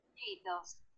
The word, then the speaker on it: hitos: Ya Yo decía hitos, pero no, hitos, hitos. Porque es H, la H es muda, mane, por Dios.